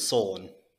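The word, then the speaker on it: Sulon: And his homeworld is Sulan. I don't know if I'm pronouncing that right. – I think it's Solan.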